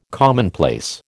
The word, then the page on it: commonplace: American English